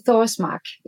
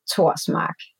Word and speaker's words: Thorsmark: In English, I say Thorsmark, yeah. Pronounced in Danish, it's Cecilie Torsmark. So you don't do the th because it's just the T. But my name in English would be Cecilia Thorsmark.